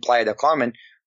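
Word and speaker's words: Playa del Carmen: They went to Playa Carmen. Am I saying it right? – Playa de Carmen.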